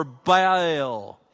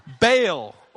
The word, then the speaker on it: Baal: By the way, Baal and Baal, either pronunciation is correct.